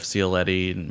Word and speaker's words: Cioletti: And you can also follow me on Twitter, and that's at Jeff Cioletti. My name is spelled C-I-O-L-E-T-T-I.